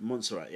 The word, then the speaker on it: Montserrat: Montserrat, yeah.